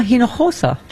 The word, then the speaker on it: Hinojosa: Okay, speaking of midterms, Gina Hinojosa.